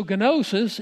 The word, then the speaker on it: gnosis: In English, we don't pronounce the first consonant when we have those two consonants together, but in Greek you do, because Greek has no silent consonants. So gnosis is simply knowledge.